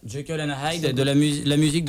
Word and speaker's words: Jekyll: On va balancer la musique Jekyll and Hyde. Je le dis bien? – Ouais. – Ou je le dis pas bien? – Ah oui, c'est Jekyll.